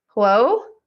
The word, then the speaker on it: Huo: It's Huo.